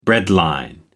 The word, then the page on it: breadline: American English